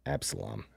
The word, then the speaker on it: Absalom: Know if I'm saying that right. Absalom. – I think you got it. – Absalom.